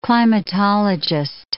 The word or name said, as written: climatologist